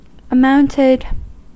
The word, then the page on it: amounted: American English